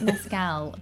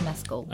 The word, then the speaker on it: Mescal: People are pronouncing it Mescal, but he has corrected Mescal. Jimmy Kimmel. – It's Mescal.